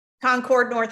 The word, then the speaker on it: Concord: Did I pronounce that right? – Concord, North Carolina.